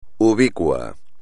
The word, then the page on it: ubicuo: espagnol